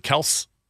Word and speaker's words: Kelce: But he's been in the league 10 years, and now he's wants everyone to pronounce it Kelce.